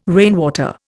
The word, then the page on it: rainwater: Indian